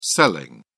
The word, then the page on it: selling: British English